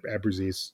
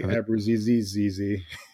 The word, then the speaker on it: Abruzzese: I say it how you just said it, Abruzzese. – Oh, okay. – I think I used to say it like Abruzzese.